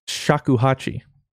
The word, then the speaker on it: shakuhachi: Shakuhachi.